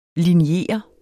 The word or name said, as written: linjere